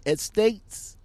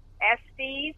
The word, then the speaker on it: Estes: I really appreciate the time that you're giving me how you pronounce it again? Estates? – I'm sorry? – How you pronounce the company name again? – Estes, Estes, Express Line. Estes.